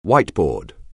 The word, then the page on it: whiteboard: anglais